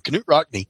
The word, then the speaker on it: Knute Rockne: You know, Notre Dame didn't play in bowls for the longest time because their coach, Knute Rockne, and I believe that's the correct pronunciation of his name, Knute Rockne,